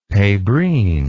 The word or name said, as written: pebrine